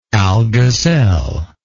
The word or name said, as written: Algazel